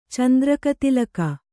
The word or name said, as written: ಚಂದ್ರಕತಿಲಕ